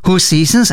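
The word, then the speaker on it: Hoseasons: Hoe Seasons, as it is pronounced,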